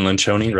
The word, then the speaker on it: Lencioni: And so there was a book that came out, Patrick Lencioni's The Working Genius. And I love anything Ren Lencioni, right? That's how you pronounce his name? – Lencioni. – Okay. – It flows, rolls off the tongue.